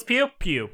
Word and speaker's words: Pugh: Florence Pugh.